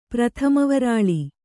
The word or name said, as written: ಪ್ರಥಮವರಾಳಿ